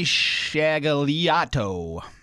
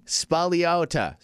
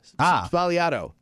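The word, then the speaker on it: Sbagliato: I don't think you pronounce the G, though. This says it's supposed to be pronounced Negroni Spagliato. Spagliato. Spagliato, that's how it's pronounced.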